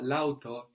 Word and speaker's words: l'auto: Because when you get the feminine la, followed by a vowel, like in auto, the A of la disappears. So actually, the car, l'auto, is spelled L'auto.